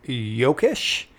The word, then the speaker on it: Jokisch: Um, the other guy that it was in the A's system for a little bit as of uh, 2018 is Eric Jokisch. Uh, it's J O K I S C H. Uh, is how that pronunciation is.